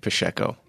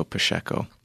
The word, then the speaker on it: Pacheco: Well, I was raised pronouncing it Pacheco, and I'll explain why. So my grandpa was from southern Brazil, and in the Portuguese language, the CHs are pronounced like an SH.